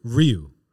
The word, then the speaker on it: Ryu: Ryu.